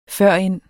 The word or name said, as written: førend